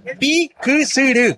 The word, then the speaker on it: Piquisirí: Es Piquisirí. – Sí, así señor. Se pronuncia bien, así como dijiste.